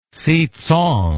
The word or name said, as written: sitsang